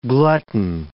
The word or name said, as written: glutton